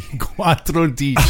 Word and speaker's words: quattordici: Reason number one, I don't remember how to say 14. – Well, let's figure it out. How do you say 10? – Well, cento, that's 100. – Quattro. – Dodici, undici, dodici, tredici, quattordici. Quattordici. – There you go.